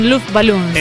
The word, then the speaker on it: Luftballons: 99 Luftballons. – Exactamente, y lo dijo en alemán, lo dijo en alemán. – Qué irrespetuosa, perdón. – Qué barbaridad, qué bien que lo pronunció, esa Luft, la Luftwaffe, muy bien.